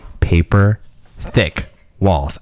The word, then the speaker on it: PaperThickWalls: That was a lovely song by PaperThickWalls.